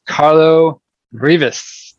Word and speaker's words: Carlo Rivis: So today, my guest's name is Carlo Rivis.